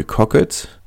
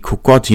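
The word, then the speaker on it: Kockott: Eine Alternative, die noch genannt wurde, war eine Beförderung für Rory Cockett oder Rory Kokott, je nachdem, wie man es aussprechen möchte,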